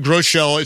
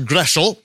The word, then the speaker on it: Groschel: For example, my name, Groschel, it's actually a German name. It should be pronounced Greschel.